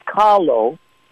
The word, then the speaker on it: kalo: First of all, taro, and many people mispronounce it. It's kalo, but the missionaries didn't change the A and the O. They just changed the K to a T and the L to an R.